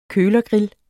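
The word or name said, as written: KØLERGRILL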